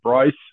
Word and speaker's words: Bryce: My name's Bryce, B-R-Y-C-E, McKinley, M-C-K-I-N-L-E-Y.